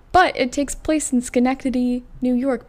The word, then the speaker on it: Schenectady: I would just like to point out that I can pronounce Schenectady correctly because it is spelled S-C-H-E-N-E-C-T-A-D-Y. And you wouldn't think that it was pronounced Schenectady, but because of my limited knowledge of the pronunciation of small towns in upstate New York, I know how to pronounce it.